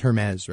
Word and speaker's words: Hermès: T-E-R-R-E space D apostrophe H-E-R-M-E-S. Is that called... That's pronounced Hermes, right? It's not H-E-R-M-E-S? – Hermes. – H-E-R-M-E-S? – Yeah.